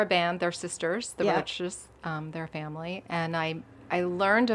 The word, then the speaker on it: Roches: Okay, so your next group is the Roaches. Am I pronouncing that correctly? – Mm-hmm.